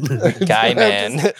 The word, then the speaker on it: Gaiman: Gaiman. Neil Gaiman. – Dude, I'm just high and can't talk. – You put the wrong today. – Emphasis on the wrong syllable.